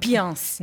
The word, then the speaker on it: Piceance: É Piceance o nome da bacia americana? – Piance. Na verdade, nem eles lá nos Estados Unidos sabiam como se pronunciava, como se pronuncia isso.